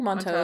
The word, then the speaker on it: Monteau: I think Monteau is probably correct. – Monteau. – French love those silent T's.